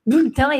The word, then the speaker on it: bulletin: E a gente fala bu, né? O bu, é o U aqui, ó, do menu, né? Bulle. Bulletin, esse E quase que não fala, né?